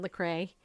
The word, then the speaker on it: Lecrae: During the almost three-hour Kingdom Legacy Live event, musicians Kirk Franklin and Lecrae, did I say that right? – Yes.